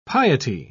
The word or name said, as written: piety